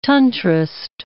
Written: Tantrist

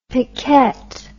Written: piquette

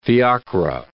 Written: fiacres

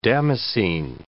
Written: damascene

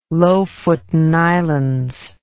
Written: Lofoten Islands

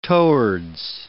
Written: towards

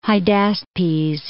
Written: Hydaspes

